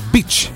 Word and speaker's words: beach: Ah, spiaggia è beach mentre donna dai facili costumi è batch.